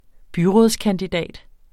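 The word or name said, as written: BYRÅDSKANDIDAT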